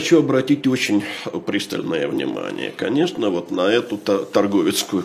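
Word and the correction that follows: Тарговицкая: конфедерацию, прошу прощения, если я делаю неправильное ударение здесь. – Ну, по-польски торговицкая.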